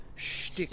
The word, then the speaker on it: shtick: My annoyance with Ryan Reynolds is, and I really like him, when he does his usual stick of like fast talking kind of smarty pants. – It's not pronounced stick, it's pronounced shtick.